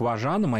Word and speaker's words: важанам: Но, тем не менее, если мы пойдем к важанам, а именно так произносится, да, во множественном числе, названия людей, относящихся к этому этносу,